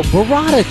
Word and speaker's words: burrata: You never heard of Bovada cheese? Is it Bovada? – No, burrata cheese. – It's Bovada. – No, it's burrata cheese. – Well, you don't have to roll your R's like a smart ass.